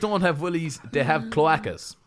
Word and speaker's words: cloacas: How do you pronounce that, Mara? Mara's here, our producer. – It's pronounced cloacas.